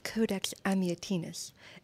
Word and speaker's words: Codex Amiatinus: This is the Codex Amiatinus.